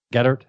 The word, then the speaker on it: Goedert: Goedert? How do you pronounce that? – Goedert. – Goedert? – Yeah. – Goedert, yeah. – How to pronounce his name.